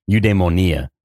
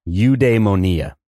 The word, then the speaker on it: eudaimonia: my own satisfaction within Stoicism or Greek eudaimonia, this human satisfaction that comes from overcoming obstacles, right? Mm. – What was that called again? How did you say that? – Eudaimonia.